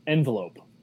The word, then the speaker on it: envelope: Next question, Peep from the Pens blog asks, how do you pronounce envelope? Which I guess gives up my answer. – Envelope versus envelope. – Yeah, I don't say envelope. – I'm an envelope guy.